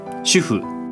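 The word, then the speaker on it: 主夫: 主に夫と書いて主婦と読む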